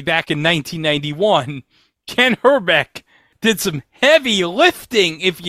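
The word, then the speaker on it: Hrbek: The pronunciation of Kent Herbeck, because he actually pronounced Herbeck almost like Trebek.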